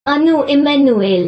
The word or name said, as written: Anu Emmanuel